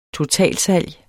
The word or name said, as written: totalsalg